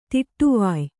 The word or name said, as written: ತಡವಾಯ್